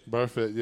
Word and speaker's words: Burfict: Vontaze Burfict? – Burfict, yeah.